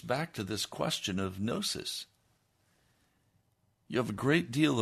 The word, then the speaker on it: gnosis: Well, as we shared earlier, and I need to review quickly for you, some people say gnosis, but really in the Greek it's gnosis. The G is silent.